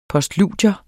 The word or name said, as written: POSTLUDIER